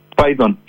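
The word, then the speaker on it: Phaidon: ¿cómo se pronuncia? ¿Faidon? – Faidon, Faidon Express.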